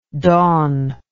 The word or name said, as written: dawnlike